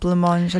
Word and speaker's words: Blancmange: I used to say, you know, just reading it as a kid, it looked like Blancmange. – Yeah. – But, you know, yourself and Kelly, you pronounce it correctly. – Yeah, with the silent C.